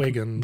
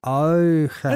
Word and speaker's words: Huygens: See, the tendency is to make Huygens. the H as well. – But right, but it's I think the H is gone. – Eugen. Oh, the H is silent? – Eugens.